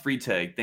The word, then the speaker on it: Freitag: Oh, Freitag. It's pronounced Freitag. Thank you, though.